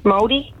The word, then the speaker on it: Māori: Maori, Māori.